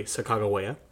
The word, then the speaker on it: Sacagawea: Not to keep on derailing this podcast, but did you know the correct pronunciation is most likely Sacagawea? – No.